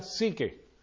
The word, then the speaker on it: psique: El alma es psuque. Se pronuncia hoy en día psique.